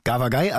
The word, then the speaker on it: Gavagai: Gawagai, also so wird es korrekt ausgesprochen, bekommt 4 von 5 Filmrollen von Knut.